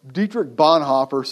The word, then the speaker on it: Dietrich Bonhoeffer: Listen to this in his book, Doing Life Together, Dietrich Bonhoeffer, if you want to pronounce it like he does, Dietrich Bonhoeffer suggests that perhaps the greatest threat to biblical community is self.